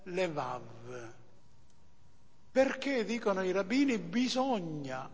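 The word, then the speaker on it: levav (לבב): Vi ricordate che in ebraico la parola cuore si dice in tuo modi leb, si pronuncia lev, e lebab, che si pronuncia levav.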